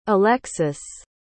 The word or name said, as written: alexys